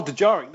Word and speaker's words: Dejarik: Something like Dejarik? Dejarik? – Oh, Dejarik. There. Thank you.